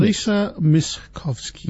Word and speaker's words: Lisa Miskovsky: I can't actually pronounce the name of the person, Lisa Muzovsky, I think. She sings the theme song, and this is a remix. – Lisa Miskovsky. – Miskovsky.